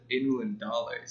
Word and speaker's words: Ainulindalë: Okay, I've always pronounced it Ainulindalë, so this is really weird for me now. Why is that so? – Why do we pronounce it that way? – Yeah. – The A-I is an I.